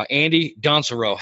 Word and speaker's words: Andy Dansereau: Andy Dansereau, how you doing, brother? – I am doing great.